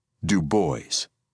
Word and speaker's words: Du Bois: Du Bois would demand that people pronounce his family's last name correctly Du Bois.